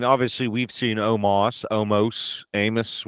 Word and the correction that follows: Omos: So I have to say his name is Omos. That's how you properly say it.